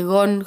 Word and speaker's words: GON: Eh, en realidad se escribe g.o.n, pero se dice GON, GON, no sé.